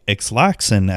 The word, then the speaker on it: Ixalan: It looks like this week, as when this recording does come out, the new Magic the Gathering expansion set called Ixalan, or Ixlaxan, as I like to pronounce it, Ixalan, is the newest theme set for Magic the Gathering.